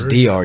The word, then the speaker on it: Drury: That's Drury. D-R-U-R-Y. Drury. – D-R-U-R-Y.